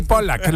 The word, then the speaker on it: Pollack: Bueno, por eso es Sidney Pollack. Se pronuncia con una L menos, pero bueno.